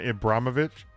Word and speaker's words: Ibrahimovic: And Zlatan Ibrahimovic. I think I said that right. – I think you did.